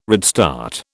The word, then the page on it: redstart: British English